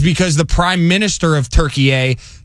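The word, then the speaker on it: Türkiye: According to Turkey A and a newspaper over there, it's been spelt and pronounced Turkey A in the Turkish Republic since around 1923.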